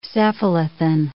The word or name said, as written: cephalothin